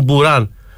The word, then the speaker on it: Μπουράν: Ο καινούριος που έπαιξε ο Μπουράν, έτσι προφέρεται το όνομά του, Μπουράν, μας το είπε ο Κώστας Παπαδόπουλος,